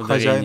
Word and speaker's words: Хозяинов: Ударение, да, Хозяинов. Ударение... Хотя э, люди умудряются частенько зачем-то вписывать э, Э, и краткую вместо...